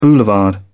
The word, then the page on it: boulevard: British English